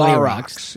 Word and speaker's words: La Roxx: So it's not L.A. La Rox. – Yeah, it's spelled L-A-R-O-X-X, but also spelled L-A space R-O-X.